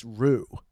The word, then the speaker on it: Ryu: So my friend told me, who is, you know, in the know of Japanese names, that it's just Ru.